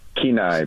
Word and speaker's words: Kenai: Kenai Peninsula.